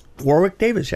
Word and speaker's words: Warwick Davis: I thought without Warwick Davis, am I saying his name right? – Warwick Davis, yeah.